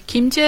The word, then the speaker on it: kimchi: ¿Sí, kimchi. ¿Lo pronuncio bien? Ajá, sí, dice bien.